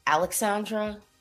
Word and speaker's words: Lxandra: Okay, it's Lxandra.